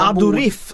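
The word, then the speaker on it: Aduriz: Adorif ska det uttalas enligt de som är väldigt, väldigt kunniga på det här.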